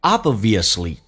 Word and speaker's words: obviously: Obviously, 这个在美语当中那个 b 可以不用发音，因为两个辅音在一起只用发后面一个，气流阻塞一下就变成 obviously.